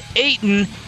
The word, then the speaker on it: Ayton: The next 50 years will begin with a guy named Ayton, which rhymes with waitin',